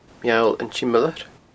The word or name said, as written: Meall an t-Siomlair